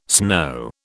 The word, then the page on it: snow: British English